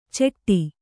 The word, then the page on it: ಚೆಟ್ಟಿ: ceṭṭi